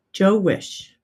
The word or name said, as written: Jo Wisch